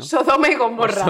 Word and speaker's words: Sodoma y Gomorra: ¿Por qué soy incapaz de ser partícipe de este Godoma y Somorra en el que vivimos? Se dice así, ¿no? Sodoma y Gomorra.